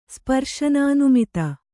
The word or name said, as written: ಸ್ಪರ್ಶನಾನುಮಿತ